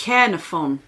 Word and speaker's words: Caernarfon: Caernarfon. – Yes. – Caernarfon.